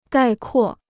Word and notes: 概括: gài kuò

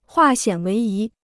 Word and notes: 化险为夷: huà xiǎn wéi yí

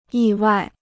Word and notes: 意外: yì wài

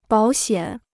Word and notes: 保险: bǎo xiǎn

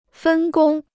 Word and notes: 分工: fēn gōng

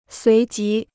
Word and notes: 随即: suí jí

